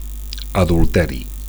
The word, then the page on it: adulteri: Catalan